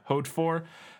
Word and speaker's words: Hautefort: It's spelled like haute, like the fashion, like H-A-U-T-E. Isn't that haute? – Yeah. – Haute four. And it's fort.